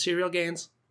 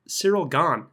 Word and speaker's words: Ciryl Gane: Uh, yeah, everyone's pronouncing his last name wrong. Everyone's calling him Serial Serial Gain, just like. But I think I, I, I, believe it's pronounced Cyril Cyril Gone is the uh, correct pronunciation there.